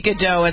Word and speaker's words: Gadot: should be Gadot. It sounds so much better. – If it was French, yeah. – Well, but it's Israeli, so they right. – pronounce the T.